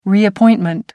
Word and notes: reappointment: American English

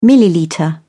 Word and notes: millilitre: British English

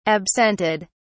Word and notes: absented: British English

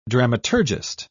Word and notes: dramaturgist: British English

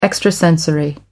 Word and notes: extrasensory: American English

